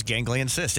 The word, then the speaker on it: ganglion cyst: Yeah, the first thing that comes up is ganglion cyst.